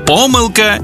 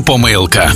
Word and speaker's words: помилка: 5 українських слів із подвійним наголосом. Байдуже, байдуже, завжди та завжди, мабуть та мабуть, також і також, помилка і помилка.